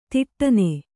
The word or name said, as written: ಥಟ್ಟನೆ